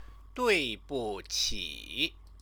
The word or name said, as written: duibuqi